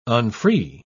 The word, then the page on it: unfree: British English